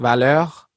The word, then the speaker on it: valeur: And then the way I came up with the name, because it's pronounced valeur, which means value in French.